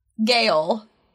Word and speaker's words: Gaol: Our hidden gem for this week is the Wicklow Historic Gael. – Gael is right, but it means jail, basically. – Gael. It's spelled G-A-O-L. – It's Gael. But, Gael. – But just say jail.